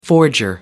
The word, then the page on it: forger: American English